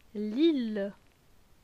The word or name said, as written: Lille